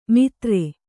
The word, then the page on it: ಮಿತ್ರೆ: mitre